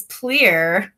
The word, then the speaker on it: Plier: Is it Plier or is it Plier? – It's Plier, but I answered all of the above because everyone always calls us Plier.